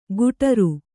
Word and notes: ಕುಠಾರು: kuṭhāru

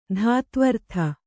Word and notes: ಧಾತ್ವರ್ಥ: dhātvartha

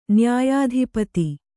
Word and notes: ನ್ಯಾಯಾಧಿಪತಿ: kannada

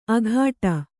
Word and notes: ಅಘಾಟ: aghāṭa

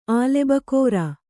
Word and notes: ಆಲೆಬಕೋರಾ: kannada

